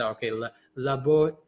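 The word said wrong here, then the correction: Labouchere: Let's see how it sounds like. Right. Laboucher. – Yeah. – Okay. Sure. Sounds really Frenchy.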